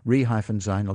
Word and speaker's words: resign: And again, if you're pronouncing them, they will be quite clearly different because re-sign is two syllables and resign, the first one is the vowel is almost lost.